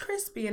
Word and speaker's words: Chrisp: And my name is Crispy, and that's spelled C H R I S P.